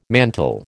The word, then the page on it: mantle: American English